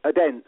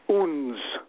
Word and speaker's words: Odense: Yeah, he told me, most authoritatively, that in fact that place in Denmark is pronounced Uns. The place that we would call Odense, or is written Odense, it's Uns.